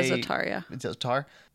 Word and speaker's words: Za'atar: Za'atar? – Yeah, Za'atar.